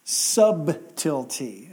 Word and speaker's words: subtle: He says, but I fear lest by any means, as the serpent beguiled Eve through his subtlety. And I always emphasize the B on that, because we say the word subtle today and we don't pronounce the B.